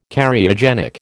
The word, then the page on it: Cariogenic: American English